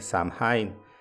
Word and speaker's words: Samhain: Esta celebración se conoce como Samhain, S-A-M-H-A-I-N, Samhain, pero al parecer como está en una, lengua vieja, pues se pronuncia como Samhain.